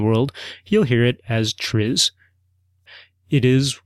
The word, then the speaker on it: TRIZ: Maybe it's time to apply some Triz on it. Triz. Capital T, capital R, capital I, capital Z. Triz. Triz is an acronym, but it's a Russian acronym, and I'm going to do my best to pronounce it.